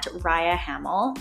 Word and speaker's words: Rhia Hamel: My username there is at Rhia Hamel. That's spelled R H I A H A M E L.